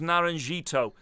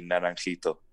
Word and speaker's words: Naranjito: Can I just pick you up on your pronunciation there? It would be Naranjito.